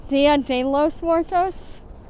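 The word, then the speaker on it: Día de los Muertos: Dia de los Muertos. – Okay. – Patrick, yeah. – Did I get it wrong? He always points out when I say that wrong. Patrick, Dia de los Muertos? – Yeah.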